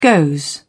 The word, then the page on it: goes: British English